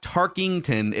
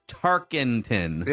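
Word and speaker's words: Tarkenton: That it's not Tarkington. It's Tarkington.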